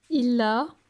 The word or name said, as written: Il a